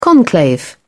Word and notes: conclave: British English

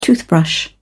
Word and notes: toothbrush: British English

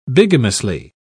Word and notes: bigamously: British English

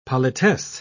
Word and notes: politesse: British English

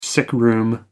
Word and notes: sickroom: American English

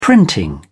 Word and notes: printing: British English

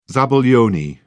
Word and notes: zabaglione: British English

